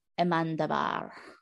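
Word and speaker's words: Amenábar: The film was written, directed and scored, talk about multi-talented, by Alejandro Amandabar.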